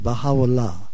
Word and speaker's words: Baha'u'llah: In Arabic, which is the language it was given, it's pronounced Baha'u'llah, glory of God, okay? – Mm-hmm.